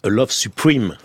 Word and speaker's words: A Love Supreme: Et il appelle son premier peep show A Love Supreme. Qu'on prononce en anglais A Love Supreme.